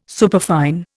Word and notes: superfine: Indian

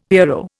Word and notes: pierrot: Indian

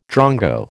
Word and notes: drongo: American English

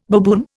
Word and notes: baboon: Indian

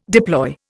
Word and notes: deploy: Indian

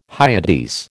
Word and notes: Hyades: American English

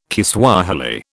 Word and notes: Kiswahili: British English

